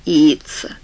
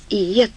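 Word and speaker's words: Yeats: Она сказала, что каждый военный должен читать Иитса. Думаю, это была шутка. Ты когда-нибудь слышал о таком, Лэн? «По-моему, да», — сказал Крейтон, рассмотрев и отвергнув мысль о том, чтобы сказать Старке, что правильно произносить надо «иец».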